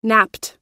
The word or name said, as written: napped